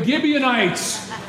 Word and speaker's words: Gibeonites: They got deceived by this group of people called the, starts with G, rhymes with Nibianites. The Gibeonites.